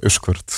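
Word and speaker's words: Usquert: Hoe, hoe spreek je dat uit? Usjaard? – Uh, Uskert. – Uskert. Uskert.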